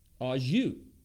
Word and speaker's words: au jus: So what is au jus? Right? I love phonetic spellings of stuff too. Like the phonetic spelling is au jus, right? So it's French. – It's spelled A-U, which is one word, J-U-S as the other.